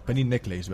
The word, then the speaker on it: Penny Necklace: Antes de nada, tengo que decir que he dicho bien el nombre en la introducción. Tenía dudas de la pronunciación, de cómo... Y creo que he acertado. Penny Necklace, ¿verdad? – Muy bien.